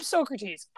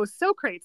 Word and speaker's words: Socrates: Man, I took philosophy in philosophy 101 in university, just for like a fun course, and it took everything in me to remember to pronounce Socrates' name as Socrates. – Yeah, you have to say it. Say it in your head Socrates.